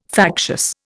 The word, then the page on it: factious: Indian